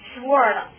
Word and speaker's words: sword: 还有一个单词呢是 sword 啊、不是 sword 哈，sword 哈。这个 L 不发，这个 W 不发音哈，都成 sword 了哈，记得。